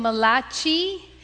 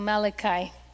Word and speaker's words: Malachi: Some people will call him Malachi, but I'll just give you the proper enunciation. He's not Malachi, although it's C-H. That C-H in Italian is pronounced ka, so Malachi.